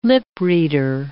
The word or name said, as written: lip-reader